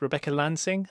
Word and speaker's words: Rebecca Lansing: So as you said, you recently announced your role as Rebecca Lansing.